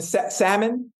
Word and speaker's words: salmon: And salmon, the L is silent.